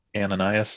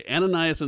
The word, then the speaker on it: Ananias: Ananias and Sapphira. – Okay, Ananias and Sapphira.